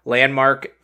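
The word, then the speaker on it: LVNDMARK: Landmark, it's spelled L-V-N-D Mark, so it's like an upside-down A.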